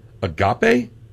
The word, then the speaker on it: Agape: Ducking Grateful, Agape, and then Ava, E-V-A. I have no idea how to pronounce it. It's A-G-A-P-E. – Agape. – Agape, yeah. – Right.